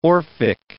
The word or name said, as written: orphic